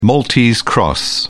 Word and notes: maltese cross: British English

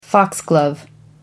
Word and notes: foxglove: American English

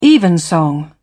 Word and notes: evensong: British English

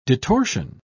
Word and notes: detorsion: British English

